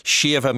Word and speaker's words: Siomha: You can find out full details on Siomha's website, Siomha Music. Siomha is spelled S-I-O-M-H-A.